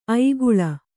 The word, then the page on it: ಐಗುಳ: aiguḷa